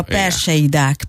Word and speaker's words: perseidák: illetve sötétedés után ezek az úgynevezett persediák, vagy persediák, nem tudom, hogy kell kiejteni pontosan, volt látható. – Magyarul a hullócsillag. – Ja. – Mert a perseidák, perseidák. De maradjunk persediáknál, ez még jobb. – Igen. – Na, de hogy ne legyünk gyagyások, perseidáknak hívják ezeket a meteorokat, hullócsillag magyarul.